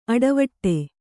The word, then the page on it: ಅಡಿವಿಡಿ: aḍiviḍi